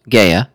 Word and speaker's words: Gueye: Uh-huh. Not gay. – Did you get that NBC pronunciation guide? – Yeah. – He said Gueye. – Gueye. – Not gay. – Got just a light ya on the end of it.